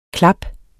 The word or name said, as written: klaps